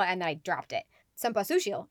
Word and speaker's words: Cempasúchil: Cempasúchil. No, Cempasúchil. There we go. The accent is on the U.